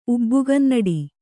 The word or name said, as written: ಉಬ್ಬುಗನ್ನಡಿ